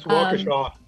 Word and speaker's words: Waukesha: It's Waukesha. – Waukesha, sure.